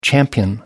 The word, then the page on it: champion: American English